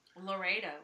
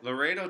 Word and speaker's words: Laredo: Laredo. – Laredo, Texas.